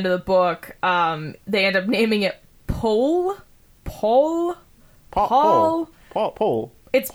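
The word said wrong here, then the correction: Pol: And it's actually Pool, Paris. It's a long O.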